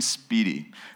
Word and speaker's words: Spiedies: I mean, the claims to fame for Binghamton are chicken Speedies. It's the best sandwich you've never eaten. It's spelled S-P-I-E-D-I-E-S, chicken Speedy.